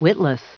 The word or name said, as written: witless